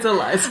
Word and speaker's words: ELISA: Isn't it pronounced ELISA? – Maybe it's ELISA. I don't know. I didn't look up a video on that one. – I'm pretty sure it's ELISA.